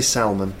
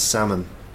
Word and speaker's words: salmon: It's a silent L. You don't say salmon, it's salmon.